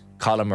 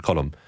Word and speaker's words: Colm: It's the pronunciation, the correct Munster pronunciation. But it is fascinating here in the office, right? So then you have Owen from Kerry, no bother to Monshane, Cullum.